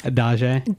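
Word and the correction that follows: Adjaye: David Adjay.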